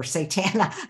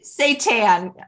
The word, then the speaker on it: seitan: What do you think of Satan or Satana? Not the Satan. – Not that. What do you think? Seitan. Is that how you pronounce it? – Yeah, seitan.